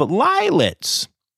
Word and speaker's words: lillets: The uh, Zoos Victoria in Australia has announced that at their Werribee Open Range Zoo, lioness Nilo has welcomed a litter of lillets. Mm-hmm. rhymes with violets.